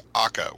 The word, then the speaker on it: Akka: How do you pronounce AKKA, A-K-K-A? I've only ever read it. – As far as I know, the pronunciation is AKKA with the more full-sounding AH sound, AKKA.